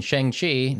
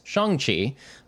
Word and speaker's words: Shang-Chi: And for all of us out there that were just English speakers going Shang-Chi, now know that it's Shang-Chi. So that's the proper pronunciation.